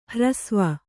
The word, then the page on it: ಹ್ರಸ್ವ: Kannada